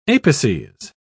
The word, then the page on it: apices: British English